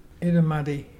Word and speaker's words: Irrumardi: Our local Mulgana word today is Itamuddy. – What is that? – Itamuddy is brim, the fish brim, and it's spelt IRRUMARDI.